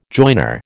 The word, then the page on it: joiner: American English